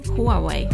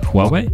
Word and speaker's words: Huawei: Kā jūs izrunājat šo vārdu? – Huawei. – Huawei? Huawei. – Huawei? Huawei. – Labi, ka jūs nesaucat viņus par Huawei.